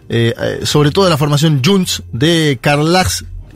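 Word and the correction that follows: Carles: Puigdemont, lo digo porque un oyente me dijo cómo se pronunciaba, Carlas Puigdemont.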